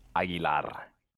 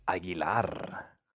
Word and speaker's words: Aguilar: So, if you want to do it the, like, 100% right, correct way? – Of course. – Aguilar. – Oh, do it again. – Aguilar.